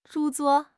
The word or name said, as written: 著作